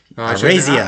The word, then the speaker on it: Araiza: Araiza.